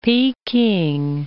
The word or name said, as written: Peking